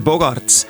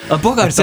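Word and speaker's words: Bogaerts: On seda korduvalt teinud ja ta on üks parimaid pesavallureid Arubalt pärit mees Xander Bogarts. – Tegelikult on Xander Bogarts. – Bogarts, ta hääldatakse, veidi Hollandi päraselt, et ma pisut uurisin seda tausta ka.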